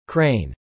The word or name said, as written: crane